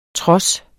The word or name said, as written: tros